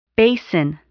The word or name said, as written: basin